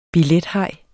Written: billethaj

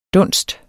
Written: dunst

